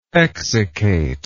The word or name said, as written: exsiccator